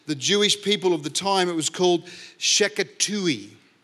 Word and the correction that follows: Shetuki: Let me get the pronunciation right. Shetuki, actually. Shetuki.